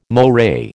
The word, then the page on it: moray: American English